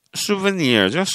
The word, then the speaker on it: souvenir: 기념품이 souvenir죠, souvenir. 예, souvenir. 영어 같지 않은 영어. 불어에서 온 것 같습니다. 프랑스어에서. souvenir, s-o-u-v-e-n-i-r. 발음하고 전혀 철자가 좀 달라요. s-o-u-v-e-n-i-r.